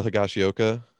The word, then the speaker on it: Higashioka: And if, you know, Kyle Higashioka, which I think I'm pronouncing that correct. – Yeah, you got it.